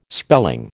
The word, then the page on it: spelling: American English